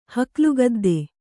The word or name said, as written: ಹಕ್ಲುಗದ್ದೆ